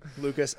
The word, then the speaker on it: Lucas: So my name, Lucas, L-U-C-A-S-A-Z-E-R, which is part of my middle name or part of my last name.